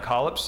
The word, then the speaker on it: collops: That's how I'm pronouncing it tonight, collops, C-O-L-L-O-P-S.